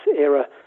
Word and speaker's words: Ira: I mean, it is spelled Ira, and some people in Belgium call her that, but it's Ira, because the film was originally called The End of an Era.